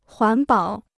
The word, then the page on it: 环保: huán bǎo